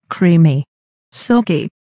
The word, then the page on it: creamy silky: American English